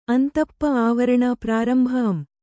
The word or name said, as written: ಅಂತಪ್ಪ(ಂ)